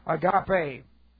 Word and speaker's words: agape: And we've looked at agape so many times, A-G-A-P, long E, agape.